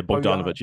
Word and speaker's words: Bogdanovich: But, like, yeah, you think about it, it's Bogdanovich. I'm not saying it wrong, eh? – Yeah, Bogdanovich, yeah.